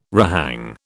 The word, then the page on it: rehang: British English